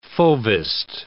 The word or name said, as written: fauvist